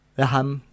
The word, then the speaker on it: Graham: Vamos a escuchar este el disco que saca Graham Nash, Graham, no sé, la H casi ni la pronuncian.